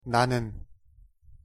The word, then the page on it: 나는: Korean